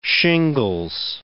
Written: shingles

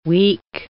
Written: weak

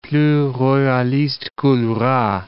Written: plus royaliste que le roi

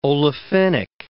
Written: olefinic

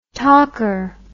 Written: talker

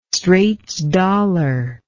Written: straits dollar

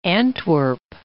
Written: Antwerp

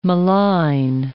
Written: malign

